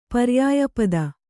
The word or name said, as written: ಪರ್ಯಾಯಪದ